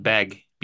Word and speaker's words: Begg: And yeah, it's pronounced like it's spelled beg, BEGG. So like the word beg, it's just they added an extra one on there.